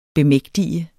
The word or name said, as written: bemægtige